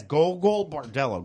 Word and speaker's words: Gogol Bordello: How do you pronounce the name? Gorgo Bordello. Golgo Bordello. – Gogol. It's read as Gogol Bordello. Gogol Bordello.